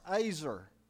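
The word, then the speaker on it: Azer (עזר): E-Z-E-R in the Hebrew. It's pronounced Azer.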